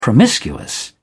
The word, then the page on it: promiscuous: British English